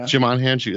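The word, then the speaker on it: Djimon Hounsou: I can't pronounce his name. – Dim John Hansu, or whatever. – Oh, yeah. – Jamon Hansu. Yeah. – Jamon Hansu. There you go.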